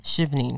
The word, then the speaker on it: Chevening: Is it Chevening or how do you pronounce that Shivning. one? Shivning.